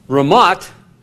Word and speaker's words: Ramoth: Ramoth, and the TH in Hebrew, is pronounced in Hebrew, has just a hard T sound.